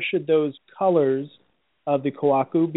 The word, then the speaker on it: kohaku: If you're looking for a kohaku, and am I saying that right, kohaku, or is it kohaku? – Kohaku. Well, kohaku.